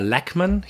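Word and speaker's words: Lachman: Das äh, äh ist von Gary Lachmann oder Lackmann. ich glaube Lackmann wird Lackman. ausgesprochen, Gary Lackmann.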